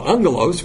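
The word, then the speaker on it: angelos (ἄγγελος): The angel, angelos, remember double G is pronounced with an N, angelos answered and said to her,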